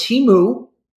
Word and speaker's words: Temu: It's pronounced Temu.